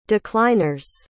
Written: decliners

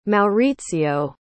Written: Maurizio

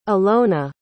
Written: alona

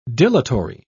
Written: dilatory

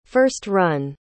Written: first-run

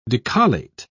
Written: decollate

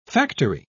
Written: factory

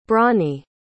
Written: bronnie

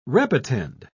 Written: repetend

